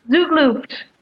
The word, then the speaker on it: Zugluft: Zugluft.